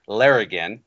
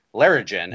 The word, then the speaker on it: Larrigan: But Ryan, in his reading, was, you know, pronounced it Larrigan. Yeah. And so what was interesting is that Richard piped up after we posted the episode and confirmed that it is a hard G sound.